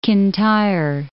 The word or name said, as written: Kintyre